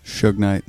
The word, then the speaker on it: Suge Knight: Suge Knight. Did I say that right? – Suge Knight.